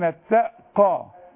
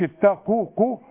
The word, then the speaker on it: اتقوا: دي للذين اتقوا مش اتقوكوا. القاف ما يعيش عليها ضمة عليها فتحة.